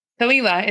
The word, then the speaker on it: Kalila: Kalila. It sounds Kalila. Like tequila.